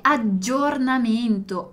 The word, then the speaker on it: aggiornamento: E poi aggiornamento, aggiornamento. Os dois Gs também é a gente dá aquela demorada, né? Então guarda quando dico giorno, um G, giorno e aggiornamento, aggiornamento.